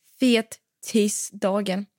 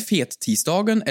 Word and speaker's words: fettisdagen: Fettisdagen. – Det här har vi diskuterat, men är det fettisdagen eller fettisdagen? – Man kan uttala det hur man vill.